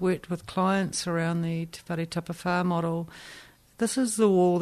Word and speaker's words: Te Whare Tapawhā: So we're talking about Mason Jury's Māori health model, Te Whare Tapawhā. Did I say that right? – Yep, Te Whare Tapawhā.